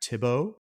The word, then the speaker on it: Thibeau: It is spelled T-H-I-B-E-A-U. That's, we'll say, Jack Thibault, who plays the role of Kruger.